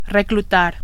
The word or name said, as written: reclutar